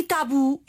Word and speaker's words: tabu: E tabu é uma palavra aguda.